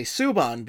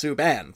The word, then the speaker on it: Subban: The Kid Clancy Award for Best Humanitarian went to PK Subban, who, again, somebody who, much like Nazem Kadri, I have trouble pronouncing because it's always Subban, and I always say Subban because that's just how my brain thinks it should be pronounced. And it's like, no, it's Subban. It's like, okay, I gotta think Canadian with this.